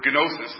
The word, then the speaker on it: gnosis: So the word knowledge here, one of the Greek words, kind of a funny word, gnosis is what it is. It's the basic root word for Gnostic, which is spelled gnostic, has the G in front. But in English, we don't pronounce the first letter.